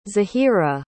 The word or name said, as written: zahira